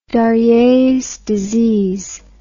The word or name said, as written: darier's disease